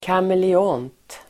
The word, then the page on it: kameleont: kamele'ån:t